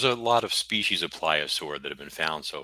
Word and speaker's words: pliosaur: So, and what do you know about how do you pronounce it? Pliosaur? – Pliosaur. – Pliosaur. – Yeah.